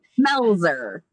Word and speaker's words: Melzer: Melzer. – Melzer. Okay. So it is like it's... – Like, it is like it's spelled.